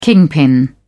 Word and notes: kingpin: British English

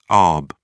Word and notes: arb: British English

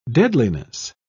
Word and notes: deadliness: British English

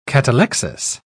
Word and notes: catalexis: British English

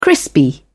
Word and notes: crispy: British English

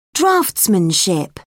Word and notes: draftsmanship: British English

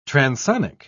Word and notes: transonic: British English

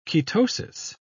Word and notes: ketosis: British English

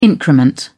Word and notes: increment: American English

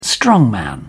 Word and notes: strongman: British English

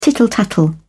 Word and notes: tittle tattle: British English

